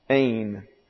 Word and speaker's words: ain: But the Holy Spirit, in inspirational guidance, employs the imperfect tense in hain, or no, that's ain, excuse me, it looks this way. And that's Ada, pronounced like a long English A. Ain. That's a smooth breathing, not hain, but ain.